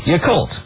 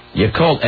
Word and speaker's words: Yacolt: Y-A-C-O-L-T. Yakult. – Yakult.